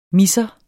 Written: misser

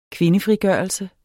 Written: kvindefrigørelse

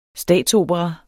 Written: statsopera

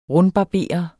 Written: rundbarbere

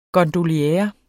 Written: gondoliere

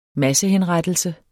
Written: massehenrettelse

